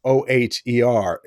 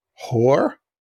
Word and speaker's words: Oher: um, he's recently come out, and the family is I pronounce it Michael Oher. Even though if you look up his name O H E R, it sounds like it should be whore.